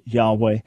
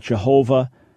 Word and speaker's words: Yahweh: We're not 100% sure it is pronounced Yahweh. It is certainly not Jehovah, but was the original pronunciation Yahweh? I believe that's the best explanation that we have.